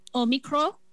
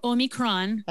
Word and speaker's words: Omicron: Well, apparently the Greeks call it Omicron. – Oh, really? – With no N, or Omicron, which is the Greek pronunciation. The English say Omicron, and Americans say Omicron or Omicron.